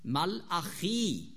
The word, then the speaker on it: Malachi: Und was heißt Malachi? Mein Bote. Malachi ist mein Bote. So spricht man Malachi auf Hebräisch aus.